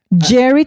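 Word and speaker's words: Gerry: How do you say his name? G-E-R-R-I. – Jerry. – Is that Jerry or Gary? – Jerry Turner. – I think it's Jerry. Yeah.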